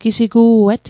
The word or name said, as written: gisigu'et